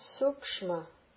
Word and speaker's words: sukshma: Then sukshma. Sukshma. Long U. The first is an S sa sound because there's no mark on it. That means it's a dental S. Sukshma. Karanam. Ka-ra-num. Lingual na. Long A. Ka-ra-num. Karanam. A-a-a.